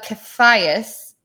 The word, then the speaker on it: Caiaphas: "Then they led Jesus from the house of Caiaphas," did I say that right? – Caiaphas, yeah.